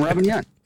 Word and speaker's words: Robin Yount: Why did I say it like that? – Because that's his name, Robin Yount. – Yount. – I say Robin Yount.